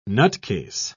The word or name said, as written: nutcase